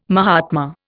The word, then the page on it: mahatma: Indian